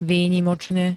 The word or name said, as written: výnimočne